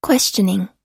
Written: questioning

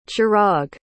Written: chirag

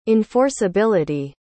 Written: enforceability

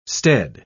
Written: stead

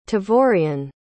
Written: tavorian

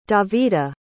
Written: davida

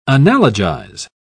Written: analogize

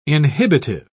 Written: inhibitive

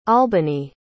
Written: albany

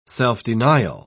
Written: self-denial

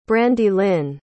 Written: brandilyn

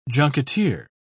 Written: junketeer

